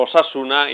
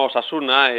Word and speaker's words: Osasuna: Es, por ejemplo, Osasuna y no Osasuna, Batasuna y no Batasuna.